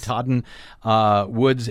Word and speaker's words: Katahdin: The Katahdin, is that how you say it? – Yes. – Katahdin Woods and Waters National Monument that Barack Obama has just declared under the Antiquities Act to be a national monument.